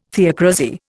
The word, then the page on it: theocrasy: Indian